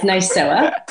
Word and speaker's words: NISOA: Did I pronounce that right? NISOA? – No, you bitched it. That's okay. I think with the accent you have, it doesn't really sound like butchering it. It sounds lovely. Maybe better than me say it. So we'll take it. It's NISOA.